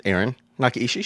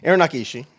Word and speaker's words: Aaron Nakaishi: My name is Aaron Nakaishi. Should I say my name? Yeah, Nakaishi. Say your last name. – Aaron Nakaishi.